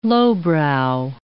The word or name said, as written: lowbrow